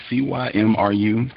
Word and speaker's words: Cymru: Uh, how do you pronounce this? C Y M R U. Could you pronounce that for me? – It's Cymru.